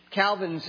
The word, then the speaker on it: Calvin: In French, Calvin's name is properly pronounced Calvin. Calvin. And it's very close to the French word dog, which is Cain or Con.